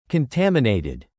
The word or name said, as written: contaminated